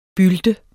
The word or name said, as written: bylt